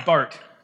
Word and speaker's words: Barth: How many of you, many, many think that it's Carl Bartha because his name is spelled B A R T H, but it is Carl Bart.